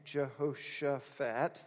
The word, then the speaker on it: Jehoshaphat: And he's got kind of a weird name, Jehoshaphat. That's really how you say it, King Jehoshaphat.